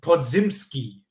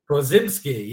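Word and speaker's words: Podziemski: I need to get the pronunciation right. It is Brandon Podzimski, I believe it is. – Podzimski, yeah.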